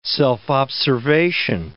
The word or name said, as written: self-observation